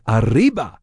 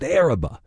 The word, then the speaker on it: Arriba: It looks like Arriba, but locals pronounce it Araba.